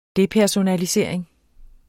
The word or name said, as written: depersonalisering